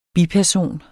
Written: BIPERSONER

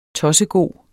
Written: tossegod

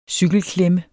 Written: cykelklemme

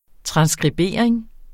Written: transskribering

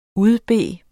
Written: udbede

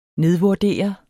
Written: nedvurdere

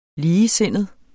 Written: ligesindet

